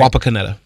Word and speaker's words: Wapakoneta: On Sunday, he'll visit Wapakoneta. Did I say that right? – Wapakoneta.